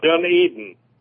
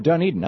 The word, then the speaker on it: Dunedin: Dunedin. – Oh, Dunedin, okay.